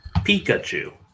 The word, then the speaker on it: Pikachu: Pikachu. – Oh, okay. All right. – Yeah. – It's an emphasis on the P, not the ka.